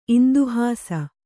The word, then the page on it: ಇಂದುಹಾಸ: Kannada